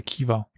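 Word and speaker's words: Chiwa: Russische Truppen zogen nämlich auf Chiva im heutigen Usbekistan zu, der Kiva oder Chiva. Also Chiva ist die Aussprache, es gibt im Englischen mit KH, im Deutschen mit CH geschrieben, nur damit man das auch sich vor Augen führen kann.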